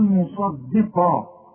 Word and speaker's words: مصدقا: الصاد مفخمه والقاف مفخمه، الدال مرققه، هنقول مصدقه احسن تطلع معاك ضاد وتقول لي مصدقه مصدي، لا مصدي، ان الدين عند الله الاسلام، الدين مصدقه.